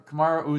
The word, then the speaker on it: Kamaru: I'm going to butcher this name for sure because I don't know how to pronounce his first name. – Usain Bolt. – Kamaru. – No, it's Kamaru.